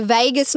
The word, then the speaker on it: vagus: The vagus nerve. – The Vegas nerve. Yeah, V-A-G-U-S. – Oh, not but, spelled the same as no, Las Vegas.